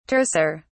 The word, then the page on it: terser: British English